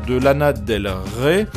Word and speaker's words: Lana Del Rey: Et justement, il a travaillé, et il travaille encore, c'est le producteur de Lana Del Rey. Je l'ai prononcé correctement, n'est-ce pas Sylvain? Salut. – Tu n'as pas fourché, c'est bien.